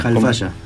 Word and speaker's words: Halfaya: ¿Alfaya lo pronuncio bien o Alfaya? – Alfaya. – Alfaya, qué internacionales. Yo me hice el que no pronunciaba como J, porque después me dicen Cipayo. – ¿Lo pronuncio bien? – Me dicen Cipayo si pronuncio el H como J.